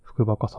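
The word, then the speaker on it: 服バカ: 洋服の服にバカ、カタカナでバカと書いて、福バカさんだそうです。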